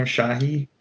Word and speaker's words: Shahi: Actress Sarah Shahi, I want to say is how you say her last name, Shahi.